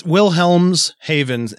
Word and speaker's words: Wilhelmshaven: I messed up already. Sorry. – No problem. – And I said it right. I just stopped saying it. – I'm like, Wilhelm sound right? That's Germany. – Lars Metank was a worker at the Wilhelmshaven. Wilhelmshaven. Well, and it's Wilhelmshaven, and it's all one word, so it looks like Wilhelmshaven.